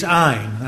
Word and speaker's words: Ayn: Uh, and it is Ayn, that's how you pronounce it. If you rhyme it with mine, you'll always remember it.